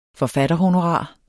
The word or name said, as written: forfatterhonorar